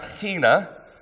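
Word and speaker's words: hina (ἵνα): The way we can determine what he's praying for is if you were to look back in the Greek, there is a Greek word that is pronounced hina. It's spelled I-N-A, but it's got an H sound on the front of it.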